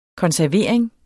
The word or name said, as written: konservering